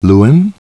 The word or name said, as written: Luan